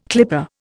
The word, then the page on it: clipper: Indian